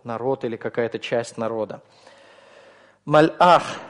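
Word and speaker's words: Мальах (מלאך): Обратите внимание на произношение этого слова. Это не малях. Малях – это он царствовал. Да? Малях, как вы помните, когда без алифа у нас. Малях. Малях – он царствовал. Но если мы добавляем сюда алиф, изменяется огласовка чуть-чуть. Мальах. Произношение будет другим уже, не малях, а мальах, да, из-за того, что вот этот вот алиф образует остановку, прерывает поток воздуха.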